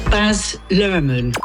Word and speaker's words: Baz Luhrmann: Baz Luhrmann.